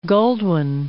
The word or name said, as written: Goldwyn